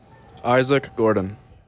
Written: Gordon

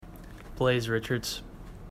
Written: Richartz

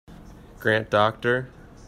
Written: Docter

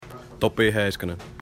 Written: Heiskanen

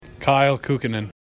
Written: Kukkonen

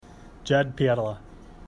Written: Pietila

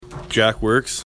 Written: Works

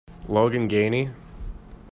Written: Ganie

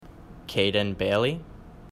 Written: Bailey

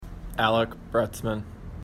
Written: Broetzman

